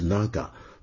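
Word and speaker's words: なが: 好，另外我们的老朋友花落人亡又提出来了，他说今日份道歉一啊，条列出来一，织田信长的日语读法呢 Naga，这个长字因为信长呢是 Nobunaga 这个长对应的日文是 Naga，读作 Naga，这是长在日语中的训读，代表长短的长，而非念作长幼的长。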